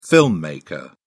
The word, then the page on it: filmmaker: British English